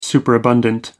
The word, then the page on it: superabundant: American English